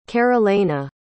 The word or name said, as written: carolena